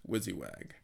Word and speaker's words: WYSIWYG: So, first up, the game is what programmers in the biz call WYSIWYG, pronounced WYSIWAG. It stands for What You See Is What You Get.